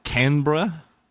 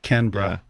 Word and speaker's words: Canberra: According to the internet, by the way, it's Canberra. – Canberra. Almost like with two syllables. Canberra. – Yeah. Or if you think of like Banbra, it's the same kind of thing.